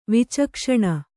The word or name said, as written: ವಿಚಕ್ಷಣೆ